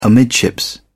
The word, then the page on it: amidships: British English